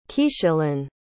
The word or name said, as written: Keashyllin